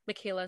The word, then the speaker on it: Michaela: Michaela is spelled like Michael with an A, and then Slinger is like singer, but with an L.